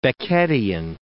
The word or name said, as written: Beckettian